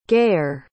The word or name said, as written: gayer